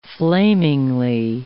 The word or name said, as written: flamingly